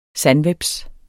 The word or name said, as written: sandhveps